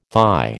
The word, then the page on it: thigh: American English